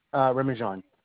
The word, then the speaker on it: Remijan: Remijan. – Spell it for me. – It's R-E-M-I-J-A-N.